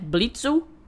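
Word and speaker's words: Blitzø: Blitzel, né? Que a gente pode falar assim, mas o O é mudo, então fica Blitz, né?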